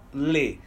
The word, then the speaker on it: les: E o plural, les. S. A pronúncia ela vai mudar um pouco, só que o singular é com a boca mais fechada, le, e o plural é com a boca mais aberta, le.